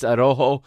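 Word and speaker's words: Araújo: In the back, he's got a center back pairing of Nestor Araujo, I believe is how they pronounce it. I want to say Araujo, but that's because I'm Portuguese. It's Araujo is how they pronounce it in Mexico.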